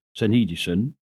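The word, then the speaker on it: syneidesis: I checked up a bit on that word, conscience, and I found out that the Greek word, which is pronounced synedison, means to perceive between.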